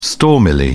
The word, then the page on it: stormily: British English